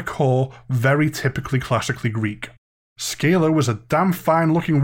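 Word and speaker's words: Scylla: God damn it, I pronounced that wrong. Skylar.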